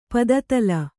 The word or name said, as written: ಪದತಳ